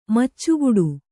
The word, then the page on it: ಮೆಚ್ಚುಗೊಡು: kannada